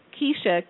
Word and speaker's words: Keisha: But it's Keisha, K-E-I. S-H-A. Natalie is N-A-T-A-L-E-E.